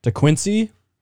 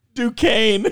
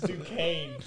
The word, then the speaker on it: Duquesne: The over, FSU versus DeQuincy. Is that how you say it? – Duquesne.